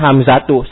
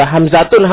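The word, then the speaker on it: hamzatu (حَمْزَةُ): Sehingga bacanya hamzatu. Salah apabila hamzatun, hamzatun. Nah, salah. Hamzatun, hamzatun, salah.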